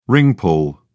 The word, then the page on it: ring pull: British English